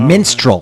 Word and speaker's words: minstrel: I think the menstrual group was code for a girlfriend and her menstruation. – Not menstrual group. No. Not menstrual caravan. Oh, minstrel.